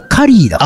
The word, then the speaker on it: curry: だから一応 CURRY はカリーだから。